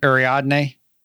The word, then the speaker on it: Ariadne: Ariadne, which is interesting because that's named after the guide through the labyrinth back in Greek mythology, okay. – I think. I can't remember. So there's the labyrinth. Ariadne is the one who guides them through the labyrinth.